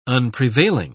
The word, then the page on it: unprevailing: British English